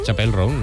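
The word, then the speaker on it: Chappell Roan: Ella misma nos explica cómo se pronuncia. – Chapel Roan. – Yeah. – Okay. – Ah, pues lo digo mal yo. – Chapel Roan. – Como Apple, Apple.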